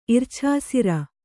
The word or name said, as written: ಇರ್ಛಾಸಿರ